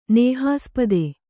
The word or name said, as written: ನೇಹಾಸ್ಪದ